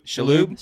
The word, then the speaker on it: Shalhoub: Shalhoub, is that how you say his name? – Shalhoub. – Shalhoub.